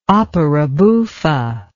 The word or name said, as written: opera buffa